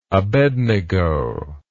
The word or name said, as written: abednego